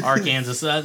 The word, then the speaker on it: Arkansas: Arkansas.